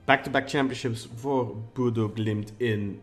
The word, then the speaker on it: Bodø/Glimt: Bodø/Glimt.